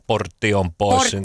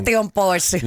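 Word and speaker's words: Portion Boys: Niin kuin Jarekin on ollut Porsin poissin, vai mitä täältä lausutaan, Portion poissin. – Portion poissin.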